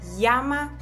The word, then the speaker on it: llama: Und das Doppel-L wird zu einem J. Also ich würde dann sagen, se llama Katrin.